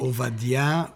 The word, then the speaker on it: Ovadia: Ovadia, questa è la pronuncia corretta, significa servo di Dio, colui che lavora per Dio.